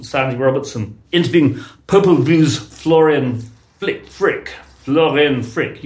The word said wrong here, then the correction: Fricke: You'd be able to pronounce that better. – Florian Fricke.